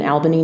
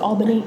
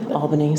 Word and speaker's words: Albany: On February 2nd, 1801, they got married in Albany, New York. – Okay. – And in it's pronounced Albany. – Albany, sorry.